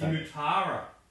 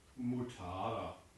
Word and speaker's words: Mutara: It's Mutara. – Oh, sorry. – You've got to get the name right. – Yeah. – Mutara.